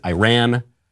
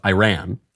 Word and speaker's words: Iran: Speaking of courageous women, the sister of the supreme leader of Iran. I did it again. I'm an American. I should pronounce it Iran. Obama is in my head. I'll try it again. The sister of the supreme leader of Iran, of the Ayatollah, has come out against her brother and against the regime.